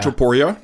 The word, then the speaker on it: Topuria: Alira Topuria.